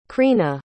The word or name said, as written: chrina